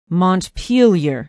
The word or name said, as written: montpelier